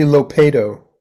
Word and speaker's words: Lopato: Actually, Lopato.